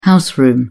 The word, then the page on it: house room: British English